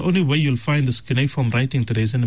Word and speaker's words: cuneiform: The word we were looking for, Morana, is the word cuneiform. – Okay. – Cuneiform. It's spelled C-U-N-E-I and then form, F-O-R-M, cuneiform.